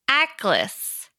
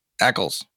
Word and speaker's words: aklys: Comes flying a weapon that I know how to pronounce, but I'll spell it so that you can pronounce it. – Shillelagh. – No, it starts with an A. A-K-L-Y-S. – Ackles. – Ackles. – It's like a throwing stick. It's a Roman javelin. – A-C-K-L-E-S? – A-K-L-Y-S. – A-K-L-Y-S.